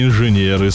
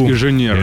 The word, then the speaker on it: инженеры: Американские инженеры собрали дрон по рисунку. – Правильно говорить, инженеры.